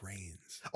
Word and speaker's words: Reigns: Head head of the table, Roman Rons uh versus Edge. Yeah. – Rome his last name is it's Reigns. Oh, I've been mispronouncing it for like a month on purpose.